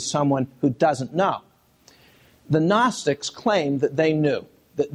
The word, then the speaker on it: Gnostics: There were, in the first and second centuries, people called Gnostics, a silent G in the front, spelled G-N-O-S-T-I-C-S in the plural.